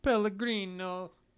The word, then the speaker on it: Pellegrino: In the book, Brune, who he says, how do you pronounce his name? – Pellegrino. – Okay, is that how you pronounce it? – Ernetti. It is. It's Pellegrino or Pellegrino.